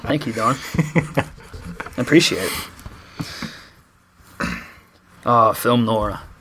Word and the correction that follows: noir: Is it noir? How do you say... – I always said Nora. – Nora? I think it's noir. Like, you is it noir? – I think it's like N-W-O-R. Noir.